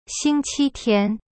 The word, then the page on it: 星期天: xing-qi-tian